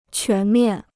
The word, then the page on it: 全面: quán miàn